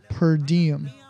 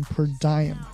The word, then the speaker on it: per diem: 好吧，下一个第三个语言点 per diem，或者也可以读成 per diem，它是拉丁语，意思就是每天 every day 或者 per day。